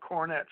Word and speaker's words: Cornet: This is something I've been wanting to tell you, that my last name is spelled Cornet, C-O-R-N-E-T. It's the same spelling as the musical brass instrument, okay, the cornet.